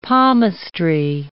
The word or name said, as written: palmistry